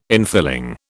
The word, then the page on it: infilling: British English